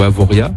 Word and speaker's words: Avoriaz: Au niveau mercure, il faut compter cet après-midi 17 à Avoriaz ou Avoria. – Avoria. – Avoria, merci. – On prononce pas le Z à la fin.